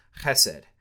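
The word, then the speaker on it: chesed (חסד): Some of you may have heard about it before, but the word is chesed. And I am going to do the guttural with it because that's how you have to pronounce it.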